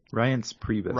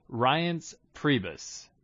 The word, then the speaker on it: Reince Priebus: How do you say his name? – Reince Priebus. – Reince, okay. Reince Priebus.